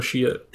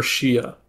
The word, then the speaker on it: Shiite: I'll go Shiite. – The Shiite? – Yeah. – They're the Shiite? – So you could pronounce it Shiite, Shiite, or Shia.